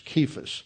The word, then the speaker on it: Cephas: There's no C that is pronounced like an S in Greek, so it's a hard, there's a hard K there in Greek. It's Cephas.